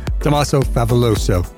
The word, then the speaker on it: Tommaso Favoloso: My name is Kimberly Holcomb, and I am here with the one and only world-famous Tommaso Favoloso, that you now know how to pronounce correctly. – Yes, see? – Tommaso Favoloso.